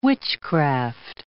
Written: witchcraft

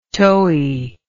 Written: toey

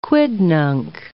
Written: quidnunc

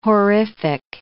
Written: horrific

